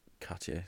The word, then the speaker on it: Cartier: I am wearing, not my own watch, the aforementioned Cartier Santos. – Oh, wow. The French pronunciation there.